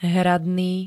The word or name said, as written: hradný